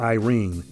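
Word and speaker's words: eirene: And the Greek word for peace is a word I believe it's pronounced Irene.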